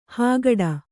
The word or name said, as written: ಹಾಗಡ